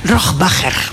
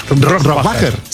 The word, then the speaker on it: Rohrbacher: Bueno, ¿cómo se pronuncia Alba Rohrbacher Coixet? – Rohrbacher. – Rohrbacher.